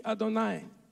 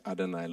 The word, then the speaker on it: Adonai: And the Jews would read, read it, where he, Adonai, Et Yosef, because they don't pronounce Yahweh. They always say Adonai, Lord or Master.